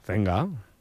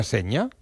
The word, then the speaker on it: Zenga: ¿Cómo se pronuncia Walter Zenga? – Zenga. – Ah, ¿no se pronuncia Seña? – No.